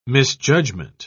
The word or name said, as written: misjudgment